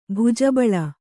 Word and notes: ಭುಜಬಲ: Kannada

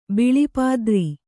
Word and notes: ಬಿಳಿಪಾದ್ರಿ: kannada

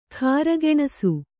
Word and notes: ಕಾರ್ಗೆಣಸು: kargeṇasu

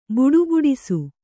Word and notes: ಬಡಬಡಿಸು: baḍa baḍisu

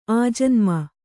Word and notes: ಆಜನ್ಮ: ājanma

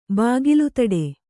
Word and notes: ಬಾಗಿಲುತಡೆ: kannada

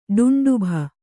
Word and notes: ಡುಂಡುಭ: ḍuṇḍubha

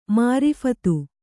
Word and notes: ಮಾರೀಫತ: Kannada

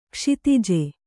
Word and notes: ಕ್ಷತಜ: Kannada

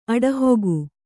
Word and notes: ಅಡಹಿಕೆ: aḍahike

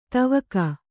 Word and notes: ಧಾವಕ: kannada

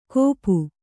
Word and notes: ಗೋಪು: Kannada

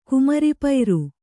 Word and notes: ಕುಮರಿಪೈರು: Kannada

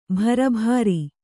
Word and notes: ಭರಭಾರಿ: bhara bhāri

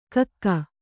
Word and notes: ಕಕ್ಕೆ: Kannada

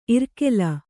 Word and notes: ಇರ್ಕೆಲ: irkela

